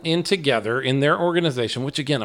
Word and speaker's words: TOGETHXR: But Together is spelled TOGETHXR. Together. – Uh-huh. – The X in place of that final E.